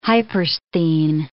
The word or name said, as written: hypersthene